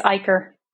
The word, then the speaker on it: Eicher: It's Eicher.